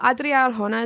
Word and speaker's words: Adria Arjona: Well, Adria Arjona is in the movie.